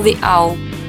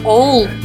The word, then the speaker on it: Owl: Ну, американец бы сказал The Owl. – The Owl. – Яу. – Яу. Яу. Ну, то есть произносится не оул, мы как бы видим, что оул написано, не так читаем. А у них это читается ау.